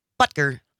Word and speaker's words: Butker: Harrison Butker. – Yes. – From the Kansas City. Emphasis on the first syllable, please.